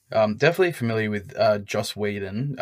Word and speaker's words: Whedon: And I should probably mention that this film was written by Joss Whedon, or Whedon. I'll never know how you pronounce his name. – Yeah, so I think it's Whedon.